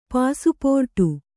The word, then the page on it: ಪಾಸುಪೋರ್ಟು: pāsu pōrṭu